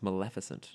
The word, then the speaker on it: Maleficent: It's pronounced Maleficent. – What did I say? Maleficent. Maleficent. It's fuck you. I'm not having this argument today. – That's not an argument, Cameron. It's right there on the text. It's spelled with an E.